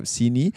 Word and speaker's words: Seeni: Kita ada app Sini. So kita ada app nama Sini. Ejaannya S-E-E-N-I.